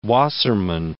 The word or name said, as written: Wassermann